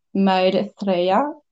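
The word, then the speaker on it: Maelle: The name is Maidthreya. So I'm going to spell it okay for you American people or English listener. It's M-A-E-L-L-E.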